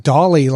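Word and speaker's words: DALL-E: I thought you were saying like Dolly, like D-A-L-L-E. I think that's how you pronounce it. Dolly.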